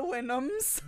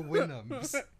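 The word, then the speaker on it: Houyhnhnms: It's the Winhams. – The Winhams. – But it's like spelled with way too many H's.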